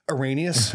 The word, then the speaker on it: Irenaeus: Irenaeus.